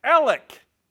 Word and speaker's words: Alex: It's spelled Alex, but it's pronounced Alec.